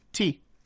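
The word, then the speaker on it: tea (茶): The exact same character, the Chinese character that he mentioned earlier, is pronounced tea.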